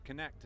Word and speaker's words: Kinekt: And so I changed the name to Connect, and it's spelled K-I-N-E-K-T. And the kin is about kinesthetics.